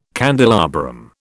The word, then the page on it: candelabrum: British English